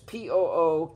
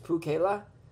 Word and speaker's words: Pookela: I wonder if Pukila is still in business. Pookela. That's not how you say it. It's Pukela. Pukela in Hawaii. Pookela.